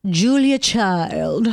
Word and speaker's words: Julia Child: And Julia in theaters, a documentary about legendary French chef Julia Child.